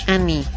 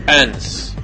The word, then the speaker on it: Anne's: Es Annie. Es Queen's Anne's Revenge. – Ya. Yeah. – No es Annie, es Anne's. Sí.